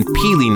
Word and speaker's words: Pili: Well, look no further than Peely Nuts. That's spelled P-I-L-I.